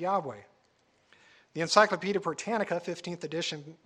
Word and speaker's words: Yahweh: Greek transcriptions also indicated that YHWH should be pronounced Yahweh.